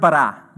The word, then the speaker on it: bara: it's just the word bara, B A R A, transliterated, meaning uh, it take, you take a Hebrew word and you turn it into an English word.